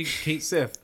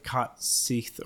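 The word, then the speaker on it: Cait Sith: No, it's officially Kate Sith. They came out and said, this is how you pronounce his name.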